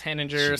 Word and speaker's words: Henninger: Yeah, I think it's _ Henninger. I can't remember, but yeah, I think it's. My dad always says Henninger.